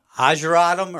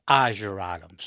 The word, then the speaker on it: ageratum: Petunias, wax leaf begonias, ageratum or ageratum, however you want to pronounce that one. My grandma always made us pronounce it ageratum. So that's how I plant say it most of the time.